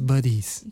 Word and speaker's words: buddies: Bodies. – Disculpa mi pronunciación. – Somos buddies.